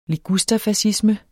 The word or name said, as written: ligusterfascisme